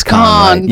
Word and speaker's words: Cannes: I always know how to pronounce it. It's Cannes.